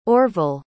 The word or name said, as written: orville